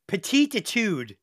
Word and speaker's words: Petite Etude: So, okay, Petite Etude. Am I pronouncing that right? – I would say yes.